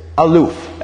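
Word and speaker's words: aloof (אלוף): But the main thing here that I want us to get is the Hebrew word for a best friend here is aloof. Everybody say aloof. Yes. So I would, how would I spell that in English letters? Probably A-L-U-F. Aloof. A-L-U-F or maybe A-L-U-P-H.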